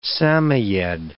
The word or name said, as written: Samoyed